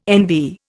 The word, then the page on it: NV: Indian